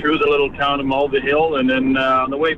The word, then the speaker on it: Mulvihill: Well, we went to, I can spell it for you, M-U-L-V-I-H-I-L-L.